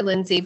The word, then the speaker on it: Lyndsie: Yeah, and I it, easier for me with email too at the schools, and it is it's Baker Lyndsie, but I'm gonna spell it out because my name is spelled a little differently.